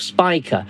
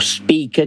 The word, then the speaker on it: Spica: On the 7th, it's at first quarter phase and lies above Spica, or Spica, depending on how you want to pronounce it.